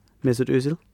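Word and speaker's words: Mesut Özil: Mesut Ozil.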